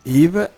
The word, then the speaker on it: Yves: che Yves è scritto Yves con la Y, sì. ma non si pronunciano sì. mai le ultime due lettere.